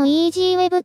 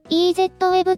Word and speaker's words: EZweb: あと、そもそも EasyWeb って読むのを知らなくて、 EZWeb って呼んでる人とかは、結構いそうな気がします。何年かしたら、インターネット老人会で、その昔、 EasyWeb